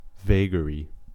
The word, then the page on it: vagary: American English